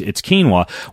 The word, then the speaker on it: quinoa: And you've no doubt heard of quinoa before, which I used to pronounce quinoa before I realized that there actually is a more traditional way to pronounce quinoa. But ultimately, it's quinoa.